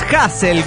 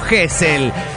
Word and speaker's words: Hassel: Mi nombre es Hassel, como se pronuncia, ¿no? Hessel. ¿Qué crees? – ¿Quién le dijo Hessel?